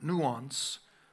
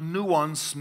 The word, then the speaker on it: nuance: nuance from each other. The word nuance, in case you're not familiar with it, comes from the French word nuance. I think you pronounce it like Beyonce.